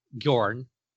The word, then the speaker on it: Bjorn: I would say Bjorn, one syllable, like fjord.